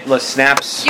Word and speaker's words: Lesnapps: Folks, this is Ginger Lesnapps. – Hello. – Did I say that right, Lesnapps? – You did.